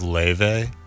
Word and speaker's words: Laufey: I don't know if it's Laufey or Leife. I think it's Laufey. Because it's obviously Norse mythology. But, oh, also... – It's pronounced Leife.